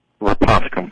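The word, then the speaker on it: Rhaponticum: Do you think that's a result of the Rapanthicum, how do you pronounce it? – Rapanthicum carotenoid.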